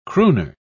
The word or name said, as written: crooner